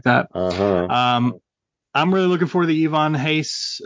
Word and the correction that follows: Ivan Reis: uh, Yvonne Re- Ivan Reis, or however we say his name these days, uh, variant, and the 1 in 25 Daniel Sampere cardstock variant with Hal and the Jets is really cool too.